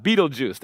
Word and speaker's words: Betelgeuse: It's called Betelgeuse. That's how you pronounce it.